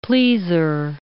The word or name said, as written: pleaser